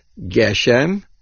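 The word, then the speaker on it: Geshem (גשם): Rav Yaakov writes that the word is pronounced Geshem with 2 segoilim,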